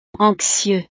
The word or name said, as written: anxieux